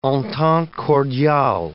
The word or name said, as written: entente cordiale